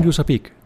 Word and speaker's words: USAPEC: En un momento regresamos para seguir desmenuzando la conversación con Yusapik. Síguenos en redes sociales como Yusapik Latam para que te enteres de nuestros eventos y las actividades que realizamos. USApic se escribe U-S-A-P-E-C. Yusapik Latam. Mito.